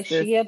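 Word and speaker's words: Xia Li: Zhai Li. Am I saying that right? – I would say Shea, but I don't. – Shea. X-I-A Lee.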